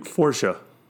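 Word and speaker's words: Fourche: Forsha.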